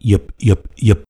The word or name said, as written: Yipyipyip